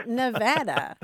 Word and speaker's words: Nevada: My story is even worse. I grew up in Missouri, and there's a town that's spelled the same, but it is pronounced Nevada. So for me, the state was Nevada for a very long time because that's how you pronounce the town in Missouri.